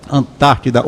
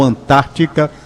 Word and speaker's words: Antártida: Antártida ou Antártica, se pronuncia as duas coisas,